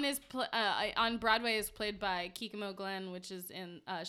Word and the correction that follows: Kimiko: Oh, Kimiko Glenn.